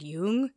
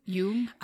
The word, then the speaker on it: Jung: And young, how did you, I always used to say young, but I know now I feel like I'm saying it wrong. – Oh, I think that's probably right. I took German and they said Jung. – Jung? – I mean, it probably is Jung. It's spelled with J-U-N-G.